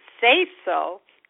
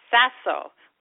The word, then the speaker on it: Sasso: I never thought my last name was hard to pronounce, but some people pronounce say so. But if you say so, that is incorrect. My name is pronounced Sasso, like sassy. It's an Italian name and it means stone.